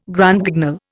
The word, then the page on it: Grand Guignol: Indian